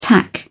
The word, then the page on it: tack: British English